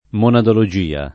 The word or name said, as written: monadologia